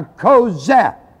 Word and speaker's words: chozeh: The second word that's used in the Bible for a prophet in the Old Testament is the word kozeh. It is spelled C-H, like a K, ko. C-H-O-Z-Z-E-H. Kozeh. C-H-O-Z-E-H.